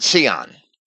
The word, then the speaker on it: Sion: Sion in Greek, that's the correct pronunciation according to James Strong's pronunciation guide found in the Greek-English dictionary section of his concordance. It's not a Z when it's spelled in Greek. It could have been, but it wasn't. It's an S, a sigma.